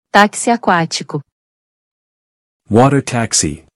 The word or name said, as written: Taxi aquatico